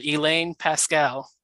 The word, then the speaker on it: Elaine Pascale: We have author Elaine Pascal.